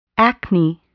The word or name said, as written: acne